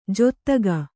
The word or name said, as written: ಜೊತ್ತಗ